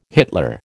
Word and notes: Hitler: American English

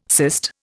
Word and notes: cyst: Indian

